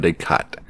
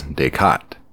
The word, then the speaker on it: Descartes: I don't know if it's Descartes or Descartes. The pronunciation guide online says Descartes, like Descartes, kind of like that English accent, no R, but there is kind of an R sort of a sound, Descartes.